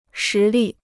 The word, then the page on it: 实力: shí lì